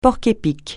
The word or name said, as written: porc-épic